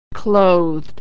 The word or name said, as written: clothed